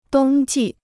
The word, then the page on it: 冬季: dōng jì